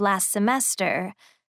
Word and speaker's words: last semester: اگه دقت کنیم، last یه T داره. ولی اینجا T تلفظ نمیشه. Last semester. دوباره اینجا T میفته.